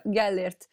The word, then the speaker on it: Gellért: And is it Gellért there? Gellért? – Yes, exactly. Yeah, okay. You pronounce it right. Yeah, Gellért.